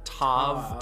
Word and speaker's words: Tav: It's pronounced Tav, Tav, Tal. So we're going to do Tav.